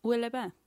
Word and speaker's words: Où est le bain: Où est le bain? Où est le bain? Which I don't think I'm even saying correctly. – No, you're saying it right.